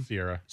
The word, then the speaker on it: Ciara: Like, it's really rad that Russell Wilson and his wife, is it Ciara? Is that how we say her name? – Sierra. – Sierra.